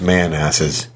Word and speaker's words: Manassas: It's pronounced Manasses.